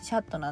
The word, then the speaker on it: chat: シャットって CHAT って書いてシャットなんだけど。って感じで、フランス語って CH の発音が、まあ、シャシュシュの音なんですよね。